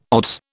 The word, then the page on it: odds: British English